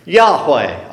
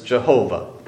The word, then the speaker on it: Yahweh: It's actually His name, Yahweh, although some people mispronounce it as Jehovah.